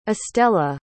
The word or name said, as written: estella